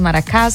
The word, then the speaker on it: Maracás: Ele é de Maracas, Bahia. Maracas, Maracás, sabe como que pronuncia? Maracás, Bahia.